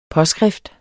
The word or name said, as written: påskrift